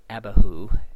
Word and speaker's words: Abihu: It's something like Nadav and Avihu. We usually say Nadab and Abihu because we can't say it the way it's supposed to be pronounced, and that'll do.